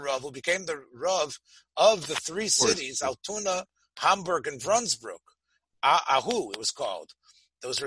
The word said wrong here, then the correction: Wandsbek: Right, Wandsbek. – Mm-hmm. I said it wrong. Wandsbrück? Wandsbek. Uh-huh.